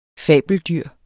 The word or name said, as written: fabeldyr